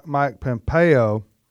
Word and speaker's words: Mike Pompeo: Hey, Sandy, I want you to comment on Mike Pompeo.